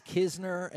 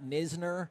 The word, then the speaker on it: Knizner: It is which makes no sense because usually you silence the K, right, and pronounce the N. I, I'm, I'm telling you, I feel very confident it is Kisner and not Nisner.